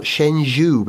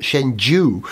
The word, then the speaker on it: Shenzhou: it's actually pronounced, not Shenzhou, but Shenzhou, which, I don't know what that means.